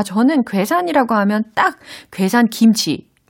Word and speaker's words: 괴산: And so this article it talks about specifically 고산. 아 괴산.